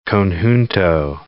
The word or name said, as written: conjunto